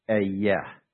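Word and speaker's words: ayah (אהיה): I will be here is translating the Hebrew, the form of the Hebrew word to be, and we say it like this. It's ayah, ayah.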